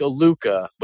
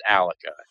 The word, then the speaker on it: Alukah: And just in case any of you guys were wondering, it is spelled A-L-U-K-A-H. It looks like a Luca, but it's pronounced Alica.